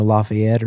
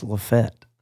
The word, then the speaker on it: LaFayette: Anywhere else in the world, that would be pronounced, you know, Lafayette or something, but from where I'm from, it's Lafayette. It's like the craziest thing.